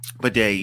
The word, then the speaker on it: bidet: that's how i pronounce it right bidet yeah have you ever used one I haven't.